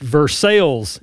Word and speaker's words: Versailles: But I will say it's pronounced Versailles in Kentucky, even though the place in France might be Versailles. – Yes. – We have taken donations from listeners in Versailles. – That's how they pronounce it, Versailles? – Yeah, they pronounce it Versailles.